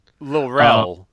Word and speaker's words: Lil Rel: I feel like we're sort of pot committed on all things that Lil Ray Howard's in, right? I think we think everything he's done we've watched. – Lil Rel. So we should get his name right if we're pot committed.